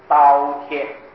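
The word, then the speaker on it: 饕餮: 下面号字头一个十字啊，念作刀啊，刀贴，针字电字头十字脚念作贴，刀贴，刀贴，刀是贪利的表现啊。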